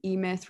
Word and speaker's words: E-Myth: I think last episode, when I just re-listened to that, you mentioned the E-Myth Revisited, or E-Myth book. Am I saying that right? – Yep. Yeah. It's the e-myth. So it's the entrepreneurial myth.